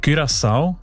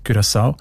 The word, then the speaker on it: Curaçao: Curaçao, das Land, habe ich gelernt, spricht man eben mit «ü» aus, wegen, weil Holländisch dort auch eine wichtige Sprache ist. Und im Holländischen ist das «ü» offenbar häufig als «ü», Curaçao.